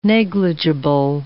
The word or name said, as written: negligible